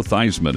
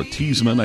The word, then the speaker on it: Theismann: Joe Theismann is 77. Or Theismann, I think, was his pronunciation until he wanted to win the Heisman.